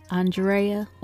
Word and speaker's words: Andrea: I am your puzzled host, Andrea, or Drea if you butcher the A-N portion of my name, as many of you are still doing.